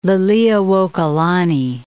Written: Liliuokalani

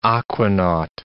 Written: aquanaut

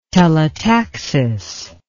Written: telotaxis

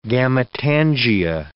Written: gametangia

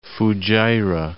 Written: Fujairah

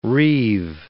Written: reeve

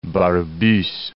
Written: Barbusse

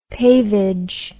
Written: pavage

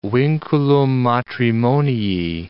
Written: vinculum matrimonii

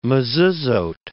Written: mezuzot

